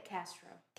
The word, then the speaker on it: Castro: How do Americans say your name? Castro? – Castro.